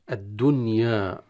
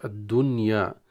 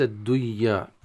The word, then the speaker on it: الدنيا: الدنيا هكذا تنطق، الدنيا وليس الدنيا، وليس الدنيا، إذا قرأت الدنيا فتدغم النون في الياء وهذا غير صحيح.